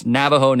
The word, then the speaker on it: Navajo: So pronounce Navajo Nation like the A in hat, not N-A-W.